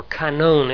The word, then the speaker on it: kanon: The Greek word that's used here is K-A-N, long O, and canon, or kanon if you prefer.